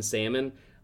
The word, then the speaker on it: salmon: pronounce the L so hard. And it would drive me nuts. And I was like, please don't. Like, if somebody pronounced the L in salmon, I knew without a doubt I was getting less than 10% tip.